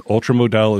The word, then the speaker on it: Ultra Modal: It says ultramodal is pronounced ultramodal.